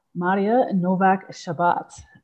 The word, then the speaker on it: Maria Nowak Szabat: So on this week's episode, I am speaking with an Ayurvedic practitioner and teacher, Maria Nowak Szabat.